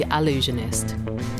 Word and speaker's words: Allusionist: This is The Allusionist. That's Allusionist with an A, not an I.